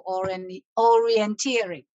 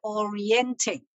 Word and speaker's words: orienteering: 野外定向系一种活动嚟噶，就系即系同足你哋解释嘅一样，不过有个中文字，而英文字系读做 orienteering，就唔系 orienting。咁我入过两个课室咧，听到老师系读 orienting 嘅，咁请大家注意一下吓，即系读音真系唔好错，尤其是 online lesson 咧，你根本唔知边个喺个课室度啊！